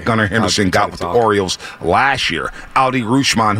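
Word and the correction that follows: Adley Rutschman: who we're going to see in the home run derby. – Adley Rushman's the pronunciation. – Adley Rushman, star catcher, one of the best in the big leagues for the Baltimore Orioles.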